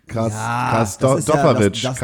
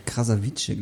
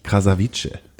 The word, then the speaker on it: Krasavice: Und du hast gerade hier was mit Katja Krasdoppowitsch. Ich kann den Nachnamen nicht aussprechen, sag mal. – Katja Krasavice, glaube ich. – Krasavice.